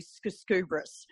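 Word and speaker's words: Skujskubris: My surname is Latvian origins and is actually Skuskubris. So it's got a silent J, S-K-U-J-S-K-U-B-R-I-S.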